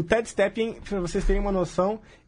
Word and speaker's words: Ted Stepien: Nesse contexto que o Victor está falando, tem um dono de, de time que ele precisa ser citado, que é o Ted Stepien, né? não sei se estou falando certo esse nome. – Ted, Ted Stepien. Stepien. Perfeito. Ted Stepien.